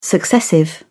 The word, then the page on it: successive: British English